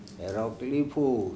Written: ErOpili

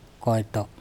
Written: koEto

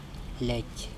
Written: lEc

